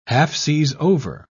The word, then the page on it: half-seas-over: British English